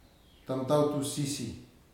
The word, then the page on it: tamtautu sisi: futuna aniwa